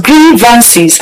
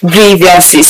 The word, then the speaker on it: grievances: Yes, so like the first segment we had, I was bringing to us the correct pronunciation of the word grievances, which is pronounced grievances and not grievances.